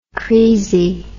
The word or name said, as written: creasey